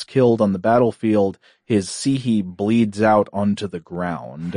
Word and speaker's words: psyche: For example, the word psihe, it's spelled psyche, you know, in the English pronunciation, psihe.